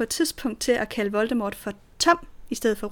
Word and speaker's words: Voldemort: øh, så skal Voldemort i virkeligheden udtales med stum T. Voldemort.